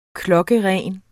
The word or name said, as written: klokkeren